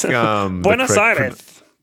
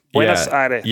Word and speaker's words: Buenos Aires: Boners Aires. – How do you say it? How are you supposed to say it? – I think... – Buenos Aires. Buenos Aires.